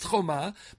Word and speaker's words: choma: It's pronounced choma, but it's spelled ches mem hey without the vav.